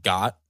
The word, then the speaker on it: khat: I also just realized when I was talking about Israel, I didn't mention that they pronounce it Ghat, there. It's like G-O-T.